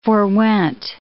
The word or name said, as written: forewent